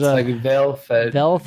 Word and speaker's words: Valfard: It's actually a educational/, I would say, content team called Team Valfard. I don't know how to pronounce it exactly because there's Valfard. – It's Swedish.